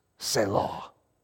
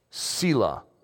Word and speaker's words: Selah: I will trust in the shelter of your wings, Selah. Or Selah, for one of our students in here. So sorry, I mispronounced that for you.